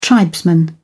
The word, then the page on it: tribesman: British English